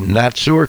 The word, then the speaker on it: Natzur: And there was part of that wave that was of the Natzur clan, excuse me, N-A-T-Z-U-R is the way we spell it.